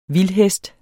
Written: vildhest

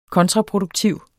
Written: KONTRAPRODUKTIV

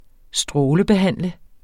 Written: strålebehandle

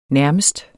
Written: nærmest

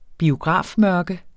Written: biografmørke

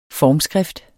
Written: formskrift

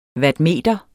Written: wattmeter

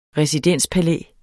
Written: residenspalæ

